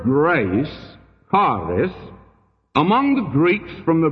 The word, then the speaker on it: charis: In the Greek, it's the word charis. We would spell it C-H-A-R-I-S. Charis.